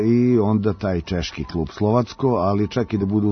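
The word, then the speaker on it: Slovácko: U grupi H će, pored crno-belih, biti Keln, Nica i Slovacko. Tako se pravilno izgovara, mi možemo da kažemo i Slovačko, ali u pitanju je ekipa iz Češke.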